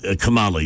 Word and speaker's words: Kamala: A, Kamala Harris. Did I pronounce that correctly? – Kamala, yeah.